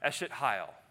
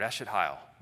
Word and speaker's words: Eshet Hayil: It's pronounced Eshet Hayil, almost like one word, Eshet Hayil. That's how you'd say it.